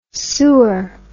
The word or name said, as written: soor